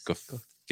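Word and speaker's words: Goethals: The first governor was George Washington Geothal. Geothal. Gothias. – Gothal. – Gothal. Pronunciation. I'm glad this isn't uh, barrel flicks. I'd be doing a shot right now. for saying it wrong three times.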